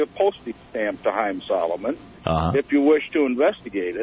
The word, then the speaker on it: Salomon: And I think the last name is spelled S-A-L-M-O-N. – Uh-huh. – More like Salomon than Solomon. But yeah. – Pronounced Solomon.